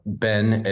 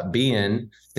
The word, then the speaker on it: Ben: If you talk to my mom, my name, Ben is Bean. It's a two-syllable Ben.